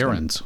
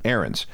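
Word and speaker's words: Ahrendts: Ahrens. – Name. Ahrens.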